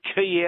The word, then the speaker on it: quay: How do you get Key out of Q-U-A-Y? – You know what?